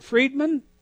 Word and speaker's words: Friedman: Is it Friedman? – You said it perfectly.